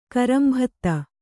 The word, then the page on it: ಕರಂಭತ್ತ: karambhatta